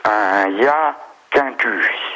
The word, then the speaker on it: Hyacinthus: Moi, je dirais un ya quintu. – Oui, et comment est-ce que vous appelez ça? – Alors, HYACINTHUS. – Ah, hyacinthus, ok, comme une hyacinthe.